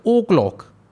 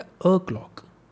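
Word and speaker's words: o'clock: क्लॉक बोलता हूँ हमेशा आपने मेरी वीडियोस को अगर देखी हो सो आई से ट्वेल्व ऑफ क्लॉक अब लोग बोलते हैं ओ क्लॉक ओ नहीं होता वो होता है अ क्लॉक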